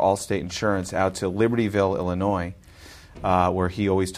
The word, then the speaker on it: Illinois: Illinois, where the S is silent.